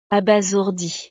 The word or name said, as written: abasourdie